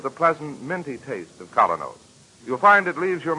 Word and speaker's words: Kolynos: That's spelled K-O-L-Y-N-O-S. Colonel's Toothpaste.